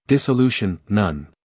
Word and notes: dissolution: ˌdɪsəˈluːʃn

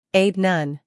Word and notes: aide: eɪd

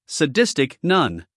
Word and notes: sadistic: səˈdɪstɪk

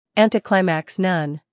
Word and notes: anticlimax: ˌæntiˈklaɪmæks

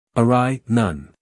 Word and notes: awry: əˈraɪ

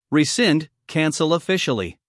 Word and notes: rescind: rɪˈsɪnd

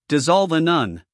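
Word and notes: dissolve: uk /dɪˈzɒlv/; en-us /dɪˈzɑːlv/

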